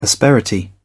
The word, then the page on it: asperity: British English